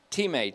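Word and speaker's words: Time (τιμή): Time, T-I-M-E, long E, means honor.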